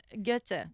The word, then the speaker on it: Goethe: Johann Goethe? – Goethe. – Goethe. Oh, my God. – Yes. – Look at you and your perfect German pronunciations.